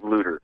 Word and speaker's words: Luder: Looter. – Yeah, and folks should know that Looter is spelled L-U-D-E-R. It's not like looter, like someone who steals during a riot. – That's exactly right, L-U-D-E-R.